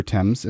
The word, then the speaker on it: Thames: Yeah, this is in London under the River Thames. Am I pronouncing that right? – You are.